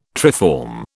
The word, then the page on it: triform: British English